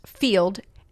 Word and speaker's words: Feeld: And number 10, I think it's pronounced Field, F-E-E-L-D. It just has a D.